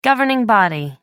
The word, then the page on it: governing body: American English